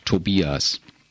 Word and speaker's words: Tobias: We pronounce it Tobias yeah. and Tobias sounds a little similar to, to ordering two beers.